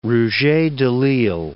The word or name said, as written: Rouget de Lisle